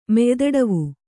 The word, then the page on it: ಮೆಯ್ದಡವು: Kannada